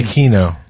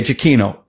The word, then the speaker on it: Giacchino: Giacchino. – Okay, Giacchino.